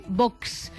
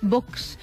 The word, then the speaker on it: Vox: Parece, oye, pero desde los pocos que pronuncia muy bien, Vox. – Vox. – Claro, será porque es su jefe de prensa y sabe que hay que pronunciarlo con cierto vos. Empaque, ¿no? O sea, Vox.